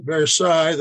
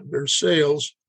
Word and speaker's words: Versailles: The, the French pronounce it Versailles, the Kentuckians call it Versailles.